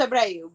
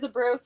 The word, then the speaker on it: Abreu: Technically, it's Abreu, but everybody says Abreu, so we're going to go with Abreu here. Just for safety.